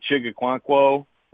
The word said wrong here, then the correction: Okonkwo: I think I just butchered the name, Okonkwo.